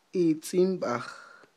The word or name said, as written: itz'inb'aj